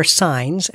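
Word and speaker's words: Syns: Next up are Signs, and that's spelled S-Y-N-S, a Sacramento band who's launched an Indiegogo campaign for a 4-song EP.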